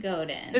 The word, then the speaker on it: Godin: Is it Godin? Or Godin. – It's Godin. – It's Godin.